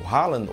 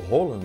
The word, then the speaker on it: Haaland: Vamos falar um pouquinho sobre essa derrota, eliminação do Brasil para o Haaland, ou Roland, né? Como ele disse, que é o certo, né? Que se Roland.